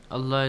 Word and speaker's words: Allah: Allah is pronounced heavily.